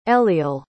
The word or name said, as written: eliel